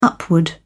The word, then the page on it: upward: British English